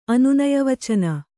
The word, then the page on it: ಅನುನಯವಚನ: Kannada